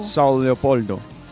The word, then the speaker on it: São Leopoldo: ¿Cómo se pronuncia eso? – São Leopoldo.